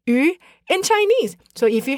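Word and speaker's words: yu (鱼): And this is because fish is pronounced yu in Chinese, which shares the same pronunciation as surplus.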